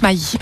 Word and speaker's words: Maddi: ça s'écrit M-A-D-D-I, mais on le prononce Maï.